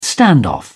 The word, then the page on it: standoff: British English